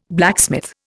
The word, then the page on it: blacksmith: Indian